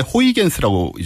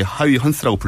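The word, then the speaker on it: Huygens: 옛날 사람인데 이 사람도 네, 이제 요즘은 네덜란드 발음으로 바꿔서 이렇게 하위 헌스라고 불러주는데 어허.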